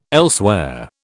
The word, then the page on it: elsewhere: British English